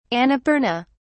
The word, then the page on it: annapurna: British English